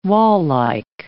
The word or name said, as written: wall-like